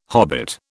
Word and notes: hobbit: British English

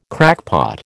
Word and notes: crackpot: American English

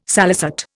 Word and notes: salicet: Indian

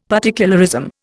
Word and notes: particularism: Indian

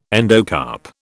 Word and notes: endocarp: British English

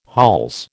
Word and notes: hols: American English

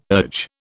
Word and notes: urge: British English

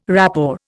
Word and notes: rapport: Indian

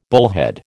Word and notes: bullhead: American English

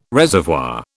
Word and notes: reservoir: British English

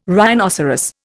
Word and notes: rhinoceros: Indian

